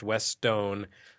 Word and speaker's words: Westone: I looked this up. I checked out the Japanese Wikipedia page. It is pronounced Westone.